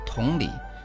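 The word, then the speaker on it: Тунли (同里): Анна, если ты любишь прогулки по каналам, то предлагаю тебе совершить путешествие в поселок Тунли, который в административном плане подчиняется Сучжоу. – Тунли? – Да, Тун читается во втором тоне, а Ли в третьем тоне.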